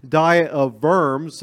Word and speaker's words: Diet of Worms: it is how it's spelled, but it's actually pronounced diet of worms, the V, not the W sound.